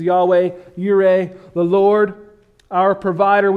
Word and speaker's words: Yahweh: God has revealed that his personal name, I believe, is pronounced Yahweh.